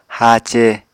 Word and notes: haace: Apatani

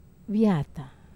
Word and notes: vata: Wamesa

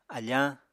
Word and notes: afañ: Apatani

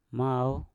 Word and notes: mau: Mpur

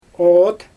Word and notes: oot: Xyzyl

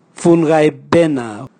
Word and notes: vungaibena: Raga

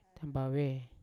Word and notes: tambave: Yawa

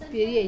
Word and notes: pirie: wamesa

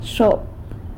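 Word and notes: xop: San Lucas Quiaviní Zapotec

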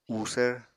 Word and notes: uusvr: Apatani

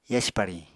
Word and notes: yasi pariñ: apatani

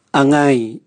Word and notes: angai: Raga

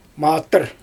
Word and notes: matyr: Xyzyl

